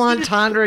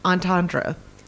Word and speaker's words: entendre: It was a double entendre, and you know it. – That's the word I was trying to think of. How do you say that? – Entendre.